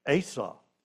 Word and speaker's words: Asaph: And one of them was named Asaph, or at least that's the way my translation tells me that I should pronounce, A-S-A-P-H, Asaph.